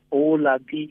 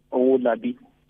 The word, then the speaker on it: Owalabi: It's Olabi. Yes, Olabi.